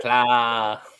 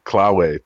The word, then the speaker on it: Klaue: That claw. – That claw. – It is spelled weird, though. It looks more like a clawway to me. – It's spelled K-L-A-U-E.